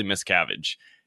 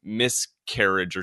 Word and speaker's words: Miscavige: And I also think he butchered the last name, which even made it a little more confusing for people. He said like miscarriage or something. Like he didn't say the name properly.